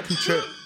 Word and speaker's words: Kutcher: It's Kutcher.